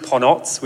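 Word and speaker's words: Ponoć: Actually, we should probably say up front, Ponoc is how the Japanese pronounce the word. It's a Croatian word that is pronounced Ponots, which means midnight.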